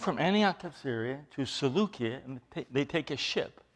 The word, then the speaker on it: Seleucia: But remember, the names of these towns, the names of these places and that, is in Greek. In Greek, the way you would pronounce that is Seleucia. There's a, what we call a kappa there, and it's, so it's a hard K, not a soft K.